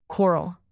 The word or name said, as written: quarrel